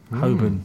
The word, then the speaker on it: Holborn: Between Holborn and Holborn or Holborn? – Holborn. – It's a good start. How do you pronounce that word? – Don't know. – It's like Marylebone. Marylebone.